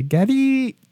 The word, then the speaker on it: Gaddis: And I'm pronouncing it Gaddis because that's how it's spelt. But in the game, they say Gaddy.